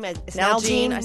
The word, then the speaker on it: Nalgene: Nalgene.